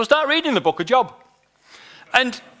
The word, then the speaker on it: Job: But it dropped open on an Old Testament book called the Book of Job. But it's spelt J-O-B. So if you saw that and you had no theological knowledge whatsoever, what would you think that word said?